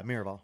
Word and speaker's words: Miraval: And I will say there were definitely moments, the Miraval trip, am I saying it correctly? – Yeah, Miraval. Yeah, yeah.